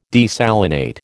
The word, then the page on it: desalinate: American English